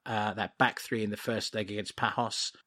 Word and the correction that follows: Paços: Have I mispronounced that? I think it's Paços.